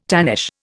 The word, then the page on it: tannish: Indian